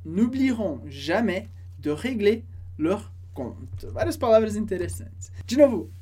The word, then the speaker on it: compte: O último comentário importante sobre compte, aparece o P na palavra, na hora de escrever, que a gente não pronuncia. A gente diz compte, compte.